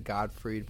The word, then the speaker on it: Gottfried: But I like that she said Gottfried, pronounced it correctly.